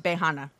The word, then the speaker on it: Beiyinhe: And that's spelled B E I Y I N H E, but it's mm-hmm. pronounced Behana.